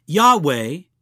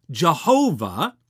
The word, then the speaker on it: Yahweh: In an older context, sometimes people pronounce that word Jehovah. You'll find that in many older English writings. But we've come to understand in the last few generations that really the more correct pronunciation of that holy name of God,